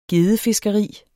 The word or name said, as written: gedde